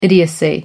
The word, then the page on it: idiocy: American English